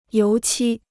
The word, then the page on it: 油漆: yóu qī